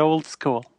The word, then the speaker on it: Oldeskuul: But we're at Old School, which is OLDESKUUL. That's how, and we pronounce that Old School.